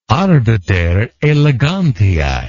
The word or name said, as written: arbiter elegantiae